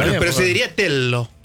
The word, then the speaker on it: Tello: Sí, es Telo. Se pronuncia Telo.